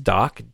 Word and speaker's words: Dok: I'm going to call it, say it's pronounced Dock, D-O-K.